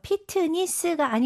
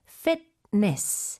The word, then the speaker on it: fitness: 네, 영어도 똑같은데 발음 주의하셔야 돼요. 피트니스가 아니고 fitness.